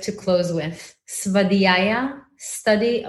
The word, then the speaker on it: Svadhyaya: Svadhyaya? – Svadhyaya.